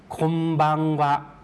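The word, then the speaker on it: ค ม บัง วะ (こんばんは): คมบังวะครับตรงคนนี้ออกเสียงยาวนิดนึงนะครับคนและตัวบังก็ออกเสียงยาวด้วยนะครับ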